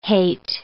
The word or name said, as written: hate